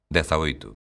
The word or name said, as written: dezaoito